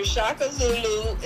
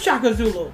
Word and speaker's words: Shaka Zulu: Ludacris manager Shaka Zulu is still in critical Shaka condition. Zulu. I said, okay, how you say it? – It's Shaka. You said it right, though.